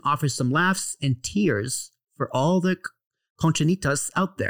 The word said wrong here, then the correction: conchitas: Did I say that right, conchinitas? – It's conchitas. – Conchitas. Okay.